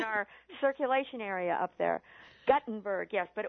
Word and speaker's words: Guttenberg: Sandy mailed in, please correct the pronunciation of the town of Guttenberg. It's not the same as the inventor. – It's Guttenberg. I should remember that.